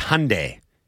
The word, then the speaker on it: Hyundai: That's Hyundai. Get the pronunciation right, folks.